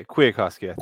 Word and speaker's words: Kwiatkowski: Like if Kwiatkowski, is that how you say it? – Yeah, Kwiatkowski. – Kwiatkowski. Cool.